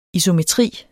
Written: isometri

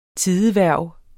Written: tidehverv

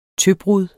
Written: tøbrud

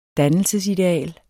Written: dannelsesideal

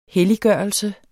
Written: helliggørelse

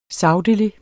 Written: sagtelig

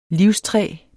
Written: livstræ

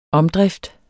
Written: omdrift

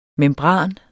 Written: membran

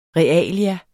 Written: realia

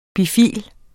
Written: bifil

